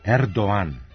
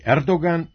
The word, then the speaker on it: Erdoğan: Turski predsjednik je Erdoğan, a ne Erdoğan. Godinama smo njegovo prezime ispravno izgovarali, ali u pravopisu se to ne može proveriti jer tamo transkripcije s turskog jezika nema. Oblik Erdoğan, koji su naši visoki zvaničnici nedavno uveli u jezik, zasnovan je na izvornom izgovoru tog prezimena u kojem se glas G ne čuje.